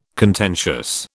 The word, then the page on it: contentious: British English